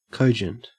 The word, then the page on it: cogent: Australian English